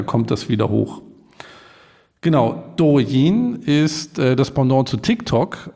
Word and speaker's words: Douyin: Das letzte Unternehmen, was ich mitgebracht habe, ist Douyin. Jetzt korrigiere ich mich selber, weil ich gemerkt habe, dass es nicht richtig ausgesprochen wird. Das letzte Unternehmen ist Douyin.